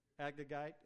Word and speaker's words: Agagite: Agagite, is that how it's pronounced? Thank you. The Agagite.